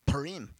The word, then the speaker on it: Purim: One is the Festival of Purim. Did I pronounce that right? Purim.